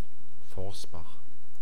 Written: Forsbach

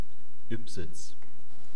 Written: Ybbsitz

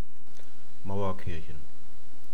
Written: mauerkirchen

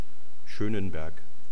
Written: schoenenberg

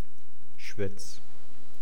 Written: schwetz

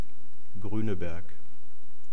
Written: grueneberg

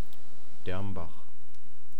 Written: Dermbach

